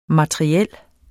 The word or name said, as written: materiel